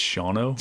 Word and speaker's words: Shawano: She was like, "It's Shawano." I was like, "It's spelled Shawano."